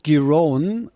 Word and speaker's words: Giron: Dritte Runde, weiß nicht, ob ich den Namen richtig ausgesprochen habe, aber das weiß Matthias Kammann sicherlich besser. Wie sieht's denn aus für den Hamburger? – Ja, er spricht sich tatsächlich Marcus Giron und Alexander Zverev tut sich bisher so leicht, wie man das erwartet hat.